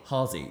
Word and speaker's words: Halsey: And he's introduced me to a lot of music that people already know, like Halsey, is that how you say her name? – Halsey. That's how I say it, yeah.